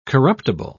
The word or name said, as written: corruptible